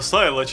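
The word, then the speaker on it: Silo: А я его Село читаю. – Село? – На состав. – Наконец-то Антон Киф пожаловал в наши эфиры, потому что иногда проскальзывают такие английские словечки. – Точно, оно ж правда Сайло читается.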